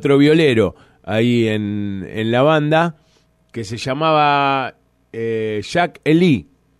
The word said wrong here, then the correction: Jake Lee: ¿no? Sería más o menos. – Sí, Jake Lee. – Esa era la pronunciación.